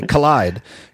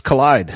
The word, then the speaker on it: Kolide: Anyway, Collide, KOLIDE. They are a software as a service startup. And oh, here it is in the notes, pronounced Collide.